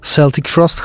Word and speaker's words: Celtic Frost: Najpierw Celtic Frost, choć Amerykanie i Anglosasi wymawiają to Celtic Frost.